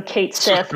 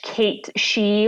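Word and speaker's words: Cait Sith: Well, I mean, we're American, so Kate Sith, but if you're a snob, it's a Scottish Kate She or Kit She, but I think we're going to go with Kate Sith.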